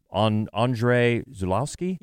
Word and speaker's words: Andrzej Żuławski: Of course, it was also, the movie's dedicated to the director, Andrzej Żuławski. – Yes. – I pronounced that right? – Good call.